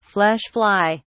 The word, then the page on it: flesh fly: British English